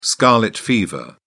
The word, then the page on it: scarlet fever: British English